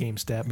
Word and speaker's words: Gamstat: It's pronounced GameStat, but it's spelled GamStat.